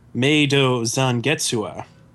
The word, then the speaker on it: Meido Zangetsuha: Inuyasha, the final act, episode three, Meido Zangetsuha.